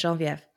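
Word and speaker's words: Geneviève: That's how French is, but it's pronounced in French, Genevieve.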